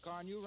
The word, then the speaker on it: Car-Nu: You can get Johnson's Car New right now at your dealers. It's spelled C-A-R-N-U.